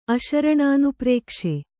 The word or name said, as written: ಅಶರಣಾನುಪ್ರೇಕ್ಷೆ